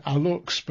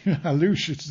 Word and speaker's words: Aluxes: I would have said Aluxes, but Aluxes is the pronunciation.